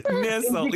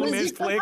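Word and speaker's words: Nestle: E tu vais até ao final, e no final, acho que é na penúltima linha, diz: o nome pronuncia-se Nessel e não Nestlé, como a companhia.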